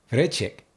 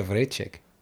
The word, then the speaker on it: Vrecek: Vrecek. Is that right? – Yes. – Okay. Alenka Vrecek.